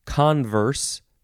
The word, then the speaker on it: converse: It, it is pronounced converse, first syllable is emphasized, noun from 1570.